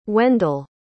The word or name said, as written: wendel